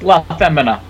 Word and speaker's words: LaFemina: It's LaFemina, but close LaFemina.